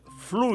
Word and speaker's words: fluido: Repare, o isqueiro está sem fluido, sem acento, e a pronúncia é fluido.